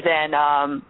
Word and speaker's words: Zhang: It's Amy Zhang.